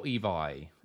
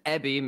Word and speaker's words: Ebi: there is no V in the Japanese language, so it's B. And ebi means shrimp in Japanese, which is why all of his social media stuff is like little shrimp thingy.